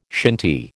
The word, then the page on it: shinty: American English